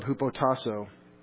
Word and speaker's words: hupotasso: Wives, same verb, hupotasso, right here. H-U-P-O-T-A-S-S, long O, is the dictionary form.